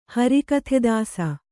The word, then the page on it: ಹರಿಕಥೆದಾಸ: Kannada